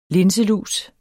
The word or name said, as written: LINSELUS